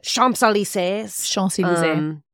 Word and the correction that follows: Champs-Élysées: That is not how you say it, but I sounded very... Champs-Élysées.